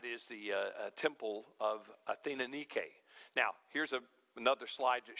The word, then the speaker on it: Nike: Both of those words derive from the same Greek word we would say today, like the shoe company, Nike. But that's not correct. If you want to pronounce it properly, it would be Nike. Nike. Last syllable gets the accent and it's long. Nike, not Nike.